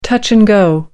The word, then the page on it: touch-and-go: American English